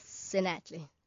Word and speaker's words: Senatle: Yes, Senatle. Bonolo Senatle is how you say it.